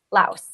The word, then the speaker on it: Laos: Laos. – Laos.